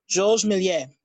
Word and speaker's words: Georges Méliès: Well, I'm not saying mine is correct, but I would pronounce it Georges Méliès. – Yeah, that one. I knew I was pronouncing it wrong.